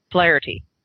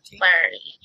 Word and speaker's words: Flaherty: Captain Flaherty, is that how you pronounce it? – His name is pronounced Flaherty. – Flaherty. – Flaherty. Because he's Irish.